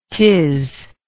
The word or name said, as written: tis